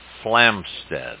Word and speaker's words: Flamsteed: Well, you know, talking about the observatory, my research indicates, David, that it was a Mr. John Flamsteed, and I'll spell that, F-L-A-M-S-T-E-E-D, from England, who was a noted astronomer, engineer, and inventor.